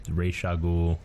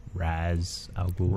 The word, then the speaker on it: Ra's al Ghul: Because it's pronounced like three different ways. – Ra's al Ghul, Ra's al Ghul, Raz al Ghul.